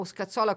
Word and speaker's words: Scazzola: l'arrivo di Scazzola o Scazzola come lo vogliamo Scazzola, sì. – Ecco, dove vogliamo mettere l'accento, e poi però da quattro partite è vero che ha avuto una concentrazione non male, però le cose non stanno andando bene.